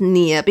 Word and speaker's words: Nir: Is it ner or is it near? N I R. – It's N I R, but is it pronounced near? Because his blog is called Nir and Far.